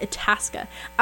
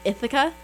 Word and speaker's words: Itasca: Itasca. I always want to say, like, Ithaca.